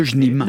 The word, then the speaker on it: JNIM: L'acronyme de ce groupe djihadiste, qui fait régner la terreur au Mali, c'est JNIM, comment on prononce ? – Le JNIM.